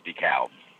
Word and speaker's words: DeKalb: I'll text you the pronunciation, but it's DeKalb.